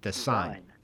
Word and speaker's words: Desyne: It's spelled D-E-S-Y-N-E. Let's say it's called the Design. – Design? – Design. Design. Oh, Design. Design spelled wrong.